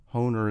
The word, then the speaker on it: Hoehner: Honer is spelled by the way, H-O-E-H-N-E-R.